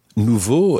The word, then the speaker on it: nouveaux: Now, nouveau in the singular is spelled N-O-U-V-E-A-U, and in the plural, exactly the same, but with an X on the end. N-O-U-V-E-A-U-X. But it sounds exactly like the singular. Nouveau.